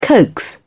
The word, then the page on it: coax: British English